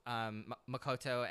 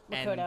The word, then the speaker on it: Makoto: They had, like, a little bit of it with Makoto and Motoko. Sorry, Makoto.